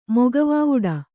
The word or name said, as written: ಮೊಗವಾವುಡ